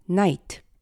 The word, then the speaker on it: night: 예를 들면 밤을 뜻하는 night, n-i-g-h-t의 gh 스펠링처럼 말이죠. 생각, t-h-o-u-g-h-t, thought, 시야, sight, s-i-g-h-t 등 현대 영어의 gh 스펠링의 묵음 현상이 궁금하셨다면 지금부터의 이야기에서 그 답을 찾으실 수 있을 것입니다.